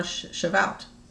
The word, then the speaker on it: Shavuot: We're going to talk about the Feast of Pentecost, or Shavuot. Did I pronounce that right? – Mm-hmm.